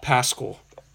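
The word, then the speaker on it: Paschall: Power forward from Villanova, Eric Paschal. – Pascal. – If I said Paschal. – Okay, it's Pascal, but...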